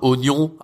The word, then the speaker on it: oignon: Oignon, avant mettait euh, un i à oignon, maintenant ils veulent enlever le i parce qu'ils se disent que ça sert à rien, on le prononce pas.